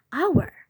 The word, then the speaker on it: hour: Yang kedua, ada kata hour yang berarti jam. Nah, bacanya itu bukan hour ataupun hour, tapi yang benar itu bacanya hour, hour, hour. Once again, huruf hanya nggak perlu dibaca.